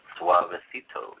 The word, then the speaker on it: Suavecito: Okay, the next song, and I'm hopefully I pronounced this correctly, Suavecito? – Suavecito, yes.